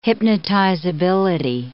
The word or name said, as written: hypnotizability